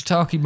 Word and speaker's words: shiitake: So something like the shake mushrooms, right? – Shiitake mushrooms.